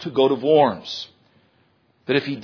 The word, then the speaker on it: Worms: Better pronunciation than Worms. Worms in Germany.